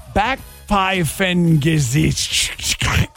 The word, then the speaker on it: Backpfeifengesicht: There is a German word pronounced Backpfeifengesicht.